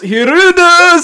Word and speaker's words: Hirudo: Distilled water plus Hirudo salt. You actually nailed the pronunciation.